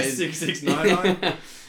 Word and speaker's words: 6ix9ine: Yeah, 6ix9ine.